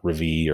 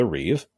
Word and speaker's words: reeve: In Anglo-Saxon England, the reeve, R-E-E-V-E, I don't know if you pronounce the third E or not, but I think it's reeve, not revie or revy.